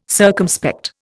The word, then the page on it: circumspect: Indian